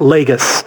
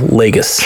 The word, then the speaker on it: Lagos: It's Lagos if it's in Portugal. The one that's in Nigeria that she damaged Lagos. Is pronounced Lagos. – That's Lagos. – Like Gus. Gus. – Like Nagus. Okay. Like Nagus from Star Trek, but with an L. Lagos.